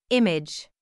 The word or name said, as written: image